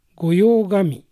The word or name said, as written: Goyogami